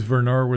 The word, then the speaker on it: Vernard: His name is Vernard with a V. – Not a B.